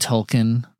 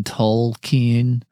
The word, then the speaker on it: Tolkien: I've always said Tolkien, but I've heard Tolkien. I've heard people really press the L in Tolkien.